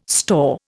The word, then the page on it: store: Indian